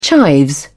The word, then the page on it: chives: British English